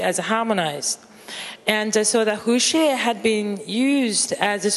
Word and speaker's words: Huxie: Maybe if you're Chinese, you can pronounce it better. – Huxie. – Yes, this is the right pronunciation.